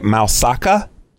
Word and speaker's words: Moussaka: Moussaka.